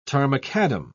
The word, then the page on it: tarmacadam: British English